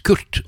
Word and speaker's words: QRT: Og det kan jo veldig lett uttales Kurt, og det meningen også.